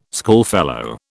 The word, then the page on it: schoolfellow: British English